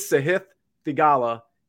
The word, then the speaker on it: Sahith Theegala: His name is Sahith Theegala, and he's 8,800.